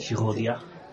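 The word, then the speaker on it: Girodias: ¿Cómo se pronuncia? ¿Girodi o Girodia? Así se escribe. Mira, te lo escribo aquí en esta, en esta servilletita. G-I-R-O... – Girodia. – Bueno, así, ¿no?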